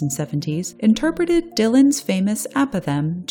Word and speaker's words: apothegm: The word is apothem. That's spelled A-P-O-T-H-E-G-M.